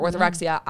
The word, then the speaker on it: orthorexia: It's called orthorexia. It's not, I've been through three eating disorders. We can break them all down. But when I was going through orthorexia, it was the obsession with eating healthy.